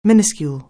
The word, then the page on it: minuscule: anglais